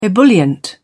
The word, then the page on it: ebullient: British English